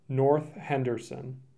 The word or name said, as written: North Henderson